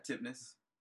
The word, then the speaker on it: Tipness: Yeah, Tipness. – It rhymes with Pitness.